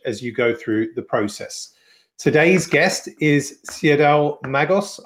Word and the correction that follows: Siadhal: And yeah, you did not pronounce that name right at all. It's Sile. So it's a strange jumble of letters, but it's pronounced like Kyle but with an S. So Sile.